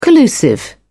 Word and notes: collusive: British English

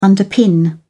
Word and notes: underpin: British English

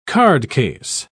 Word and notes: cardcase: British English

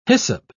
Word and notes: hyssop: British English

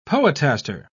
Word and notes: poetaster: British English

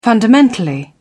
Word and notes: fundamentally: British English

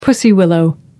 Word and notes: pussy willow: American English

